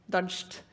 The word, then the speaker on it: Dozhd: used to be um, a journalist uh, working for and a TV host uh, in TV Rain, or Dozhd, as I believe it's pronounced.